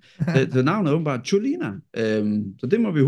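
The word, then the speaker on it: Čolina: Fordi jeg troede jo, han hed David Kuliner. Det er ikke sådan, det udtales, fordi det er jo specielt C. Jeg ved ikke, hvad det C det hedder. Øh, men det udtales åbenbart Cholina.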